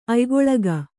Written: ಅಯ್ಗೊಳಗ

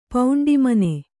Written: ಪೌಂಡಿಮನೆ

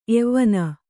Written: ಎವ್ವನ

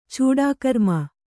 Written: ಚೂಡಾಕರ್ಮ

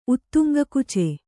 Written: ಉತ್ತುಂಗಕುಚೆ

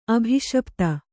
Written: ಅಭಿಶಪಿತ

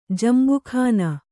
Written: ಜಂಬುಖಾನ

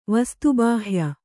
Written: ವಸ್ತುಬಾಹ್ಯ

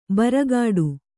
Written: ಭ್ರೂಕೋಟಿ

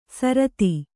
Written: ಸಾರತಿ

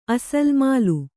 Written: ಅಸಲ್ ಮಾಲು

